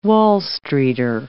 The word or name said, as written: Wall Streeter